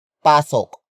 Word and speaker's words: pasok: Pasukin is spelled as P-A-S-U-K-I-N. It is a command to enter a place. Pasukin comes from the root word pasok. Pasok. Pasok. Which is spelled as P-A-S-O-K.